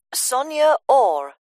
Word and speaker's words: Sonia Orr: Sonia Orr. – S-O-N-Y... – No, I'll spell it. S-O-N-I-A. Then, or is O-R-R.